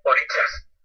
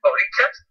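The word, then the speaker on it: orishas: Oh, mira, aquí la cuestión es que su pronunciación es con una S con una tilde hacia abajo, hacia la izquierda. Y en realidad esa dice orichas. Su pronunciación correcta es aunque se orichas, aunque se escribe orisa.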